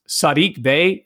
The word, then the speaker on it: Saddiq Bey: Saddiq Bey, am I pronouncing that right? – Yeah, yeah.